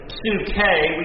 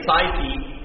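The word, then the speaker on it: psuche (ψυχή): The word soul is translated from the Greek word, we would pronounce it psuche, we say psyche.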